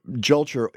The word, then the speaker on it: Julcher: People will remember Julcher if only because they won't remember it. They'll say, oh, I know that name. It's, Rhymes with culture. – Rhymes mm-hmm. with culture.